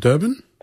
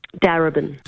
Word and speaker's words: Darebin: Durban? – Darebin.